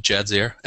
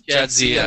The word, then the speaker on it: Jadzia: Could we play Connect the Dots with Jadzir? Anyways. – With Jadzir? – Dax. – Jadzia? – Jadzia.